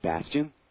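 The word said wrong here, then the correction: Bastien: Bastine. – Bastine. – Yes. – I'm trying to get the pronunciation correct. – Yes, well, that's the correct.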